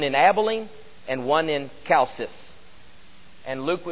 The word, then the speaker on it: Chalcis: Licinius was actually the ruler of, and I think this is pronounced with as a K, Chalcis, some 50 years earlier.